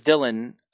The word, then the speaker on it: Dylan: So my name is Dylan, but it's pronounced Dylon on everything because I had a friend in like high school whose parents are from Germany and they can't pronounce my name.